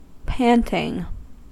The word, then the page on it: panting: American English